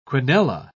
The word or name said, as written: quinella